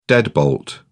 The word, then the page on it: deadbolt: British English